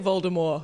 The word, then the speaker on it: Voldemort: Oh, like Voldemort. – Like no teeth? – It's like Voldemort. – Yeah. That's the correct pronunciation. – Duke Nougat. – Is it? – Yeah. Yeah. J.K. Rowling like a year ago was like, hey, by the way, the tea is silent.